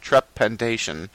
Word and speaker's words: trepanation: T-trepanation? – Spell it for me. – T-R-E-P-A-N-A-T-I-O-N. Panation. – Yeah, trepanation. – Trepanation. Okay.